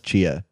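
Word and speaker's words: Tchia: Is that how you say it? – Oh, yeah. I mean, I think it's Chia. – Chia? – Yeah. – Okay, Chia. – T is silent.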